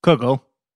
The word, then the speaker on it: Kugel: Kugel. – The phonetic pronunciation I have here is kugel.